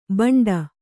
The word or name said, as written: ಬಂಡಿ